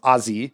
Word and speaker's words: Azee: From the Hammerhead, Amida and one of her top pilots, whose name is Azi, A-Z-E-E, pronounced Azi,